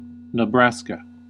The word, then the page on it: Nebraska: American English; /nɪ̈ˈbræskə/